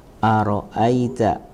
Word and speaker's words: Ara'aita: Jadi dalam bacanya Ara'aita, aita di sini lin ya. Dibaca dengan lin lembut, Ara'aita allazi yukadzibu ya.